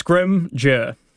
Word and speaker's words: Scrimger: So it's pretty simple. Mm-hmm. Two syllables. Yep. Don't overthink it. – Yes. – Scrimger.